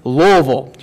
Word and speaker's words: Louisville: I didn't say Louisville. It's Louisville. Just as few syllables as possible.